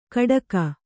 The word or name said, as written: ಕಡಕ